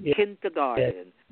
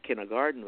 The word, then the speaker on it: Kindergarten: kindergarten. – Yes. – People say today kindergarten, but that's mispronounced. Kindergarten, which means the caring and training of the young folks.